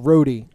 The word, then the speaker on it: Rode: By the way, Matt, my last name is pronounced Rody. – Ah, Rody. I should know that by now, right? Rody, but it looks R-O-D-E. – It does look like road.